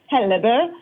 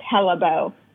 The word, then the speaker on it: Hellebø: So no, so my husband is Norwegian, so the Norwegian pronunciation is Hellebø. But if you say it like any American, it's just Hellebø.